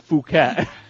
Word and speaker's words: Phuket: It's actually pronounced Phuket.